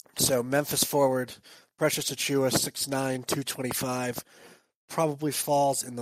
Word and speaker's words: Achiuwa: Let me try to say this correctly. Precious Achiuwa. – Achiuwa. – Ah, I was so close. Okay. Like you're anyway, chewing go ahead on and, food. Okay, like chewing on food.